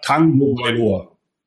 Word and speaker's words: Tagovailoa: So all together it would be pronounced Tua Tagovailoa.